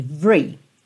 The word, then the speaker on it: every: Então, essa palavra every Por norma, por padrão, não tem o E do meio pronunciado. Lembra lá do The Police, outra aula que tá aqui na série Aprenda Inglês com Música? Every breath you take. Every, every. Você pensa como se fosse VR. Vri, vri. Mas, às vezes, você ouve every, every. E não é à toa. Muito menos é um erro.